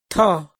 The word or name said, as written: thoj